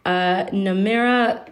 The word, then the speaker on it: Namerah: Her name is Namira Saud Fatmi.